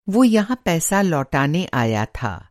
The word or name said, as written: लौटाना